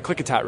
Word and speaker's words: Klickitat: Klickitat River.